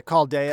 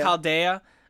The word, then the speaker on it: Chaldea: How do they pronounce it? – Yeah, Chaldea. – Chaldea, which is basically their headquarters in Antarctica or whatever.